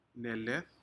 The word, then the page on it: neled: Aneityum